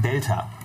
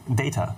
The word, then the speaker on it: Data: Ich, ich, dachte da, ich dachte, Data heißt Delta, weil ich konnte das nicht richtig raushören von der Aussprache von denen und Delta ist Wie heißt für mich... denn wirklich? – Data.